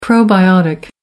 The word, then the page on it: probiotic: American English